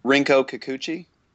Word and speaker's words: Rinko Kikuchi: Rinko Kikuchi.